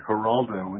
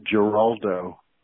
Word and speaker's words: Geraldo: And one of the names is pronounced with an H as in Geraldo, and the other one is pronounced with a G as in Geraldo.